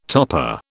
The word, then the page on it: topper: British English